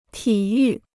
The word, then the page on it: 体育: tǐ yù